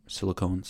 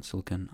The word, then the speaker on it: silicon: the Apple silicon chip, silicone, silicon. I don't know. I say silicone, but they say silicon.